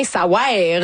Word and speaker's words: Ça ouaire: Ça veut rien, ça ouaire. Vraiment, ça s'écrit de même, faut le prononcer comme ça.